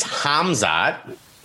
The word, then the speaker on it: Khamzat: Basically, the K is silent. It's Khamzat. Chimaev.